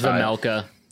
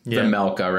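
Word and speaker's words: Vejmelka: I think it's Vezmelka. – Vezmelka, right? – I think the J sound.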